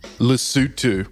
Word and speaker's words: Lesotho: the Leothos. Did I even say that right? My pronunciation? – Lesotho. – Oh, really? Is that how you say it? – That's it. – Lesotho. Lesotho.